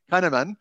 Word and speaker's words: Kahneman: How do you pronounce that surname again? Kahneman.